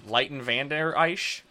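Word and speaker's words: Leighton Vander Esch: Leighton Van Der Esch? – Yeah.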